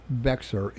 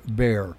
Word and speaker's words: Bexar: Now, it's spelled Bexar, but it's not Bexar, it's Bexar.